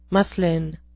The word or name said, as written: muslin